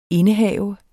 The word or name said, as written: indehave